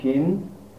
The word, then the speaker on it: Shin: We've said before that this letter, Shin, the Hebrew letter, if it is pointed on the right-hand arm, the sinister one heraldically, it is pronounced Shin.